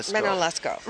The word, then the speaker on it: Manon Lescaut: Manon Lescaut.